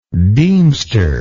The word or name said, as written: deemstership